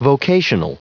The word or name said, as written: vocational